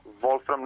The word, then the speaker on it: Wolfram: Do naziva Wolfram, samo ispravit ću vas, molim vas, mi se zovemo Wolfram, znači bez tog nekog akcentovanja.